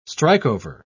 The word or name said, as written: strikeover